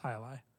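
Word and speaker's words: jai alai: It looks like J L I J A I A L A I, but it's pronounced high lie.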